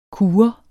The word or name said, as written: kure